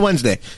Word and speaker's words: Wednesday: How do you spell Wednesday? W-E-A-D. – Wednesday. – I know, that's what I'm saying. It's weird that the D is silent. That makes no sense.